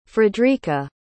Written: fredrika

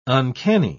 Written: uncanny

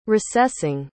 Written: recessing

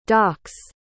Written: dock's

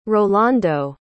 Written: rolando

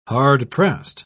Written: hard-pressed

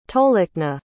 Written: tolikna

